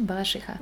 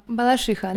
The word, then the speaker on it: Балашиха: Правильно произносить Балашиха. – У, меня какие-то в шихе. Что? Балашиха? – Балашиха, да.